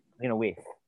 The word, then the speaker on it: Lena Waithe: Lena Waithe. – Yeah. Lena Waithe. I think you pronounced it. Okay. – Lena Waithe.